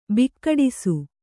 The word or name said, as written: ಬಿಕ್ಕಡಿಸು